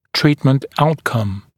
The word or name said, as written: treatment outcome